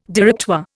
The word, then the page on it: Directoire: Indian